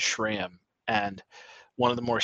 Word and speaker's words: SRAM: And I think I butchered that. I think I said SRAM. SRAM. – Yes. – SRAM.